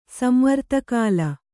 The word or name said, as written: ಸಂವರ್ತಕಾಲ